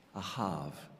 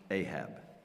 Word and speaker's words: Ahab: So today, although the man's name is Ahav, we are so used to his name, he is so familiar to us in English, that is how we will pronounce it. His name is Ahab.